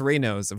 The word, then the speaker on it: Raynaud's: From from the uh, Raynaud's. Where he couldn't disease. feel his hands. – Yeah, and it's pronounced Reynolds, which surprises me, but it mm-hmm.